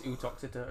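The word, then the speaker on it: Uttoxeter: Uxeter. – Oh. – It's Utoxeter, actually.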